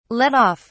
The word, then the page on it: let off: British English